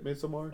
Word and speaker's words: Midsommar: Have y'all seen Midsommar? Is it that how you say it? Midsommar? – Oh, yeah.